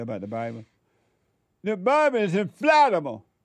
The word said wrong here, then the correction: infallible: And I try to correct you saying infallible. – Infallible. – Like Alabama. – Did I say it right? Infallible. – That's correct. Twice. You've said it right twice.